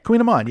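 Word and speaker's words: kouign-amann: and Queen Amans are suddenly, or how do you say it? – Queen Amon, yeah.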